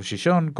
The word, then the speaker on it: Xixón: Lo que pasa es que en este caso el tema de Gijón, o Xixón, como se pronuncia en Astur,